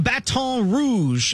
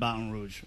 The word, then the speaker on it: Baton Rouge: Jonathan Zaslow, ESPN Radio host, joining us live from Baton Rouge.